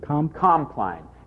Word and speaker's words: Compline: Can you mention again the name of that Compline or Compline? It's spelled C-O-M-P-L-I-N-E.